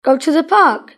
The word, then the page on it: go to the park: British English